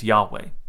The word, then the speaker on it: Yahweh: which most people believe to be pronounced as Yahweh.